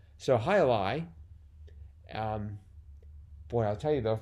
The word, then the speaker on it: jai alai: It's pronounced I lie. – Is it? – I believe. – Oh my gosh. See, most of these words, you know, I've never heard anyone say. They just exist in crosswords for my. So it's Hai Alai? – I think so. – Oh, okay, okay. – That's how I've always heard it.